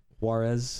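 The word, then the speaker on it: Juárez: Juarez. – Juarez. – Yeah. – I thought you said Juarez. – No. It's either Juarez or Juarez. Ciudad Juarez. But, yeah. There's a reason Juarez is the main city in the movie Sicario.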